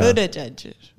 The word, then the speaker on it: Buttigieg: Buttigieg. – Mm-hmm. It's boot, like shoe, and then edge, like two of the guitarists from U2.